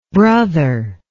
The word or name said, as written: brotherlike